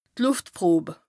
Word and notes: Lùftprob: Bas Rhin

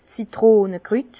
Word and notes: 's Zitronekrüt: Bas Rhin; Herrlisheim

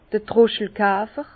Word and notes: d'r Troschelkafer: Bas-Rhin; Herrlisheim

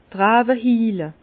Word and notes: d' Rawe hiile: Bas-Rhin; Herrlisheim

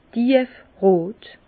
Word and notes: tief rot: Bas-Rhin; Herrlisheim

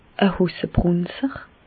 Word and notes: e Hossebrùnzer: Bas-Rhin; Reichshoffen

